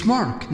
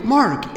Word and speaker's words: Mark: It's Mark, not Mark. Mark, M-A-R-K.